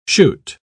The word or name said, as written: chute